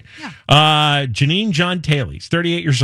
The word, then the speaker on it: Jeanine: And the guy's name, are we still calling him Janine? Or is there some weird pronunciation for that? – Yes, that is still what we're calling him. – J-E-A-N-I-N-E.